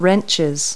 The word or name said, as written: wrenches